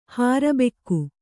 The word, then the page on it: ಹರ್ಬುಗೆ: harbuge